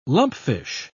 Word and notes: lumpfish: British English

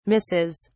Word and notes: misses: British English

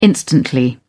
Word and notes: instantly: British English

